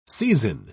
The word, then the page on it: seizin: British English